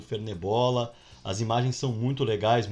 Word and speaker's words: Fernet Bola: Arroba Fernet, F-E-R-N-E-T, bola. Fernet Bola, mas se fala Fernebola.